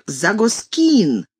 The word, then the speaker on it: Загоскин: Как внезапно среди зрителей, не вытерпев посрамления соотечественников, поднялся «Der russische Dichter steht auf der Загоскин» с ударением на «кин».